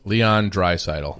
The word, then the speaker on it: Leon Draisaitl: Leon Draisaitl.